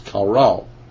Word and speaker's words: qara: The word here is kara, Q-A-R-A, spelled in the English and pronounced kara.